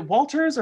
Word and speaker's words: Walters: During filming, Julie Walter, is it Walters or Waters? How do you pronounce her last name? – It's Walters.